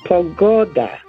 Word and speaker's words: pogoda: Pogoda, pogoda, weather. Emphasis on the second last syllable.